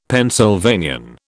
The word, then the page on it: Pennsylvanian: British English